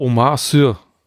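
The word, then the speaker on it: Omar Sy: Wie heißt der? Omar Sur heißt er. – So, so wird er ausgesprochen?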